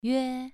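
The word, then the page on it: yue: tone 2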